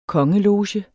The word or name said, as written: kongeloge